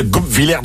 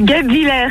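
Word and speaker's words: Guebwiller: On y est presque, la prononciation est pas ouf. – Oh là là là là là là. Vous avez raison. Je sais pas parler. Oh là, comment qu'on prononce? – C'est Goupvillers.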